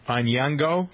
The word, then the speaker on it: Onyango: It's spelled O-N-Y-A-N-G-O. So how'd you pronounce it? Onyango? – Yeah. – Onyango.